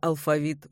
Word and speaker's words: алфавит: Преподавателю языка, даже иностранного, следует знать, как произносится слово «алфавит» в русском. Только так, алфавит. В орфоэпическом словаре под редакцией Аванесова рядом с вариантом «алфавит» стоит строгая помета «не рекомендуется».